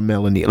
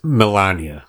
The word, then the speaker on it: Melania: Is it Melania or Melanie? Like, how do you say Mrs. Trump's name? – I don't care. – Okay, fine. I believe it's Melania. – Melania, because my daughter corrected me. So Melania.